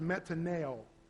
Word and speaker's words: metanoia: One is metanoia, and it's spelled M-E-T-A-N-O-I-A. Metanoia. It sounds a little different than what it looks like. M-E-T-A-N-O-I-A.